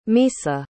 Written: misa

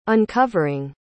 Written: uncovering

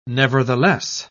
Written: nevertheless

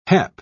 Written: hep

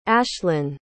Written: ashlyn

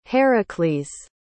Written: Heracles

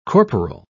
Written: corporal

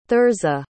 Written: thirza

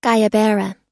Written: guayabera

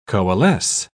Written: coalesce

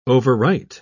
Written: overwrite

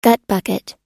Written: gutbucket

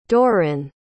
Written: Dorin

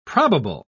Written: probable

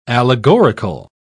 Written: allegorical

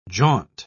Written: jaunt

